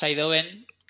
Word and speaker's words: Idoven: Pues normalmente decimos Idoven, que es la pronunciación inglesa, pero realmente se escribe Idoven, o sea que las dos son buenas.